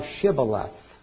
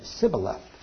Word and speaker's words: Shibboleth: So they said unto him, well, say now Shibboleth. And he said, Sibboleth, for he could not frame to pronounce it right.